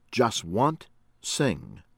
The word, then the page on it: Jaswant Singh: American English